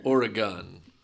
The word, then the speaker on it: Oregon: Yeah, it's gun. Like G-U-N. – Oregon.